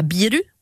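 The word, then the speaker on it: biru (ビール): Donc bière, on dira biru.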